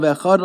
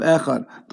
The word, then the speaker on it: Echad: Don't forget the ches of Echad. Don't forget the dalet of Echad.